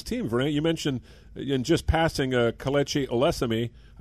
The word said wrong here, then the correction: Osemele: I believe that's how you pronounce his name, or Osemele. Osemele, got an unusual name, an offensive line.